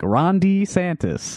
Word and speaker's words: DeSantis: Everyone I've ever heard say that name says Ron DeSantis. – Yes. – And he's like Ron DeSantis. the long E sound.